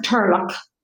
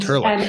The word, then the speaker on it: turlough: So, it's pronounced turlock, and turlock.